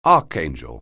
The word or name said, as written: archangel